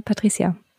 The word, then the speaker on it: Patricia: richtiger, mein nicht spitznamiger Name ist ja Patricia. – Mhm. Und Mit C wohlgemerkt. Mit C wohlgemerkt. Nicht, nicht Patricia. Sondern genau, Patricia. nicht mit Z, nicht mit Patricia, sondern Patricia. – Ähm, spanische Aussprache tatsächlich.